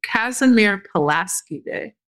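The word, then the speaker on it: Casimir Pulaski Day: I want to see if I can make sure I say it correctly. Kazimir Pulaski Day. – Seems right.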